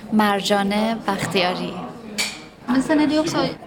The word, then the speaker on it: Marjane Bakhtiari: För jag, inte ens när jag själv pratar, även om jag uttalar J-et i Marjane.